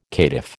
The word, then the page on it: caitiff: American English